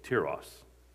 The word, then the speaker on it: Tiras: That's how you say it, Tiras, according to the guy on my phone that reads the Bible.